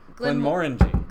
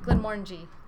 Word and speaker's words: Glenmorangie: But if you got a little extra portion of Glenmorangie, might be worth it. – Saying it different every time. – Remember, orangey. – Orangey. – It rhymes with orangey. – Glenmorangie. Glenmorangie. – Glenmorangie.